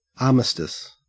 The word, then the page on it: armistice: Australian English